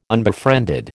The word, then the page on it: unbefriended: American English